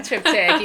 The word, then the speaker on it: triptych: That's called a triptych, right? – Yeah, it is called a triptych. You got it.